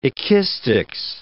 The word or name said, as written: ekistics